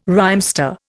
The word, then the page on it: rhymester: Indian